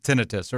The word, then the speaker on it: tinnitus: The true definition or the true pronunciation is... Tinnitus.